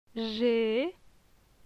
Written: Je

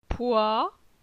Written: Poi

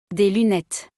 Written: Des lunettes

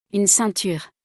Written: Une ceinture

